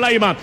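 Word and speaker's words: Laimer: Se escribe Laima. Aquí la tiene Laima. Se pronuncia Laima.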